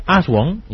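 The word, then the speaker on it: Aswang: Que yo, como domino varios idiomas, yo te puedo decir que se pronuncia Aswan y ya lo dejamos así...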